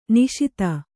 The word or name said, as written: ನಿಶೀಥ